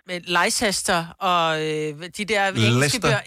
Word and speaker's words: Leicester: Altså, der er jo bare nogle ting, du ikke udtaler. Det staves jo også sådan noget Leicester, men bliver bare udtalt Leicester.